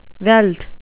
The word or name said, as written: veld